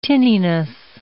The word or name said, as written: tinniness